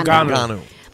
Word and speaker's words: Ngannou: Ngannou.